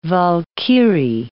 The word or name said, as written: Walkyrie